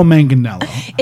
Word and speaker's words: Manganiello: Joe Manganiello.